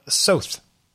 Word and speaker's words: Soth: And this would be, Alec, Soth. – Rhymes with both.